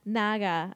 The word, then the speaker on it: Naga: And so you can go on the wiki and it's pronounced Naga. I was right.